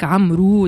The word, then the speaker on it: عمرو: عمر كي تبدا زايده واو باش ننطقوها عمر وكان ما فماش واو تولي عمر فانت عمر دياب هذاك عمرو دياب هو عمر دياب مرحبا